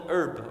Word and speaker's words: eruv: It's called an herb, or I can't pronounce it very well, E-R-U-V. And it connects different buildings to form, symbolically, one building.